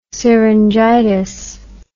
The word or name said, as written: syringitis